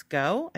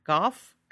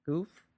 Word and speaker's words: Gough: The girls are really great, but also the mother, Sarah, played by Denise Goh. I think that's how you say her name. G-O-U-G-H? – Goh? Yeah. – Goff? Goh? Goof? – Yeah. One of those. Probably not Goof. But not Goof? Okay. We're going to go with Goh.